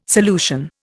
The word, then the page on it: solution: Indian